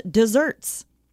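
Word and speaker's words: deserts: Also, while we're on the topic, just desserts is things you deserve, and that's why it's spelled with one S, even though it's pronounced desserts.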